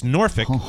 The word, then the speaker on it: Norfolk: It doesn't actually rhyme with the word folk. It's Norfolk or Norfolk if you want to sound like you're from Virginia.